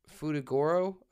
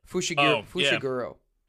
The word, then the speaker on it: Fushiguro: Futagoro? Fushiguro.